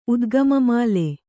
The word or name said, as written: ಉದ್ಗಮಮಾಲೆ